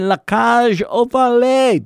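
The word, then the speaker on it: La Cage aux Folles: They're doing La Cage aux Folies. Did I say that right, Andrew? I don't know how to pronounce that. – Yeah, it sounded right.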